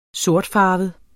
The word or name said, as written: sortfarvet